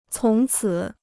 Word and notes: 从此: cóng cǐ

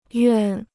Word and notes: 愿: yuàn

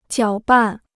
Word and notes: 搅拌: jiǎo bàn

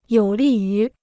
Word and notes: 有利于: yǒu lì yú

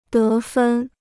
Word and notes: 得分: dé fēn

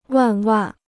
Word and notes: 万万: wàn wàn

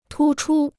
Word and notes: 突出: tū chū

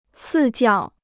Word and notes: 赐教: cì jiào

